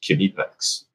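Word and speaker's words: Knipex: And he's like, and I'm like, how do you say it? Come on, tell me. He's like, yeah, it's Kinipex.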